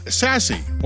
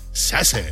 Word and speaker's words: SASE: Pronounced SASE, or as I like to call it, SASE.